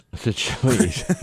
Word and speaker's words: Seychelles: That's the first time I've ever heard it pronounced correctly because it's spelled Seychelles. – Seychelles.